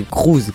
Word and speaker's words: Cruise: C'est écrit Cruise, mais c'est Cruise que ça me se dit.